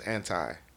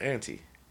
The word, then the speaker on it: Anti: Rihanna's last album was Anti, but some people call it Anti. If you hear Drake on that verse, he used both of them pronunciations.